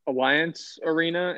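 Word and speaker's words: Allianz Arena: All right, with my second pick, I'm going to go with, I think I'm pronouncing this correctly, Alliance Arena. It's like Allianz.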